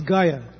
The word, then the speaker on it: Gaya: This king is Gaya, G-A-Y-A, no long, G short A, Y short A, King Gaya.